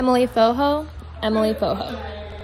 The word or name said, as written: Emily Fojo